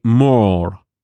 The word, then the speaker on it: Moor: También si queréis pasar miedo, podéis echar un vistazo al tráiler de The More. Esto suena un poquito a chiquito la calzada, la verdad. Se escribe como suena, more, o sea, una M, una letra O, otra letra O y una R.